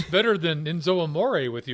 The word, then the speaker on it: Enzo Amore: Enzo Amore.